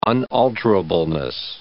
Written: unalterableness

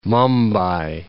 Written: Mumbai

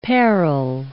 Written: parral

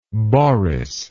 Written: Boris

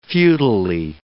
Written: futilely

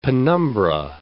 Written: penumbra

